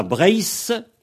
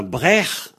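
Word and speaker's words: Breizh: Alors donc, on a Brace, en Cornouaillais en Trégor, et on a Brech en Ventais. Le ZH, il se prononce H en Ventais.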